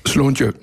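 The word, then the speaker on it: slàinte: Slontieu.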